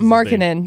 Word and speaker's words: Markkanen: Lauri Markkanen. – Thank you. – Markkanen, I think. – Markkanen. There it is. – Lauri Markkanen.